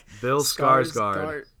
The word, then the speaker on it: Skarsgård: That's why Bill Skarsgård.